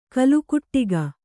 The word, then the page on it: ಕೋಲುಕುಟುಕ: kannada